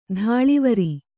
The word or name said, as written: ಧಾಳಿವರಿ